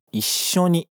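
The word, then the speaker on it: 一緒に: Juntos, em japonês, é Ishoni. E aqui tem aquele vazio na pronúncia de novo, entre o I e o Sho.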